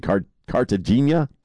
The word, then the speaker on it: Cartagena: Cartagena.